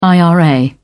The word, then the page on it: ira: British English